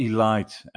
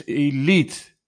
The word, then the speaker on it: Elite: And note to myself, it's not elite. And Joachim sent me an email and said to me, like, hey, say it right. It's elite, not elite.